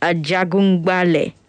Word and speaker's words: Ajagungbale: Ajagungbale. Okay. All right. Yeah. And according to Rotimi, I got the pronunciation correctly.